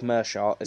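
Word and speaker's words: Meerschaert: Literally, as I said, he's fought Rhys McKee, John Phillips, and then, I forgot his name, is Gerald Merchart, is how you pronounce it.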